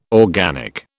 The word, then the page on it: organic: British English